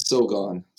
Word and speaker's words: Sogon: It's I think it's Sogon. – Sogon. – A very American way of saying it, but there's really no right way to say it, because it is actually my Korean name.